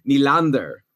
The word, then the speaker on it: Nylander: and he said it's William Nylander. Okay, that's the Swedish pronunciation.